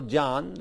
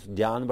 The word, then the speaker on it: jnana: Jnana, the word, or jnana, rather, pronunciation correct is jnana, but these days in Sanskrit also they say jnana for many reasons. This jnana, J-N-A-N, this is the knowledge of the absolute, knowledge about God.